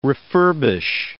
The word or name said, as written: refurbish